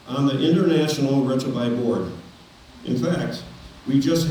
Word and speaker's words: Retrouvaille: It is spelled R-E-T-R. O-U-V-A-I-L-L-E. It's a French word that means to rediscover. Marie and I have been involved in Retrovi since 2013.